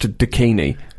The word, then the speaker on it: DKNY: It's spelt D-K-N-Y, but it's pronounced Dakini, which is really something.